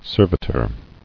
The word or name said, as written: Servitorship